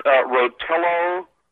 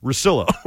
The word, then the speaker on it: Russillo: Is this Rotillo or Rotillo or Rodillo or That was John Dennis attempting to pronounce Russillo.